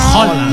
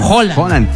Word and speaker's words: Haaland: Que se pronuncia Haaland. Doble A en noruego ha sido O. Erling, sí, Haaland.